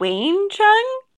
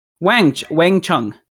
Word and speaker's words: Wang Chung: Is it Wayne Chung? – Wang Chung. Chun. – Everybody Wang Chung. – Like W-A-N-G? – Yeah.